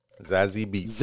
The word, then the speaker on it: Zazie Beetz: Zazie Beetz.